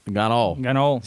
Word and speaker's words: cannoli: So, you get a cannoli. – Ganol. See how we go back to our other ones, right? Yep. Our words, our pronunciations, the C's become a G.